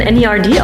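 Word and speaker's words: N.E.R.D: Team Eminem, Nerd. Ich dachte, spricht man NERD aus, aber auch der Nerd, ja.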